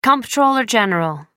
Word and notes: comptroller general: American English

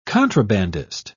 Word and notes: contrabandist: British English

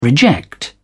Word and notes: reject: British English

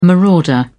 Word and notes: marauder: British English

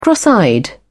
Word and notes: cross eyed: British English

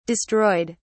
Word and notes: destroyed: British English

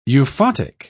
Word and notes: euphotic: British English

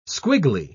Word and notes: squiggly: British English

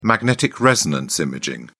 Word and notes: magnetic resonance imaging: British English

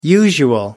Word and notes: usual: American English

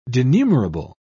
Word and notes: denumerable: British English

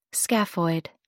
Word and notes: scaphoid: American English

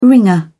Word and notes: wringer: British English

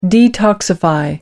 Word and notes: detoxify: British English

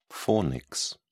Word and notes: fornix: British English

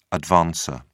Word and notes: advancer: British English

